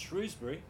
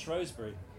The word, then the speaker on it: Shrewsbury: An army led by the Lancastrian King Henry IV, who wanted it pronounced Shrewsbury, triumphed over a rebel army led by Henry Harry Hotspur Percy from Northumberland, who wanted it pronounced Shrewsbury.